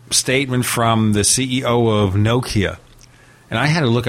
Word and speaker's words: Nokia: So it's Nokia with the emphasis on the first syllable, ladies and gentlemen. I used to call it Nokia, but now I learned it's Nokia. – And well, it's kind of Nokia in the United States. I think in Finland they say Nokia.